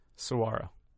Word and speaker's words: saguaro: Saguaro.